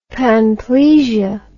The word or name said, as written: panplegia